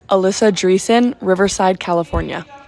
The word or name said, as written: Alyssa Dreessen